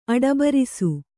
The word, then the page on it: ಅಡಬರಿಸು: Kannada